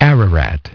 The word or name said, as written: Ararat